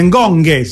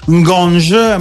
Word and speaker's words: Ngonge: Il Napoli che oggi ha presentato con Mazzocchi e Ngonghe, si pronuncia così, l'ha detto lui, quindi non si pronuncia alla francese Ngonge, ma Ngonghe, come se ci fosse una H dopo la G.